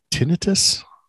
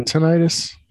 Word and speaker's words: tinnitus: It's actually pronounced tinnitus. – Oh. – Well, it's not tinnitus.